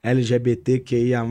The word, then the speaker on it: LGBTQIA: A galera LGBTQIA, eu falei certo? – Falou.